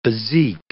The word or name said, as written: bezique